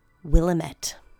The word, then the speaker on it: Willamette: And Portlanders listening, don't freak out at me for pronunciation. It's spelled with an I, not an A. Willamette.